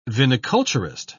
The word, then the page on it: viniculturist: British English